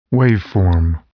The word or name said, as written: waveform